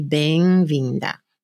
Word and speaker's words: Bemvinda: Et j'avoue que franchement, ça m'avait surprise parce qu'elle avait avec l'accent portugais, c'est Benvinda.